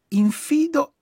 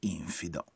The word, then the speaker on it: infido: Secondo questo libro, la pronuncia corretta delle seguenti parole sarebbe amaca e non amaca e infido e non infido. Entrambe con l'accento sulla seconda vocale e non sulla prima.